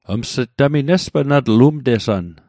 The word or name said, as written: umtesann